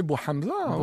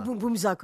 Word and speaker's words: Bouhamza: Hein, il fallait prononcer Bouhamza. – Et pas Boumboumza, comme je dis à un moment donné.